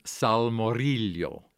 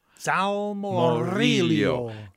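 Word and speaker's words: salmoriglio: And the name of the sauce is Salmorillo. – Salmorillo. – And it's spelled Salmorillo. – That's a Riglio. That? I saw that G in there.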